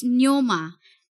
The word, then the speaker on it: pneuma: I don't know how to say this, but it's pneuma. Parang pneuma. Pneumonia. Oh. Parang ganun na spelling. – P-N-E-U-M-A. – Oo.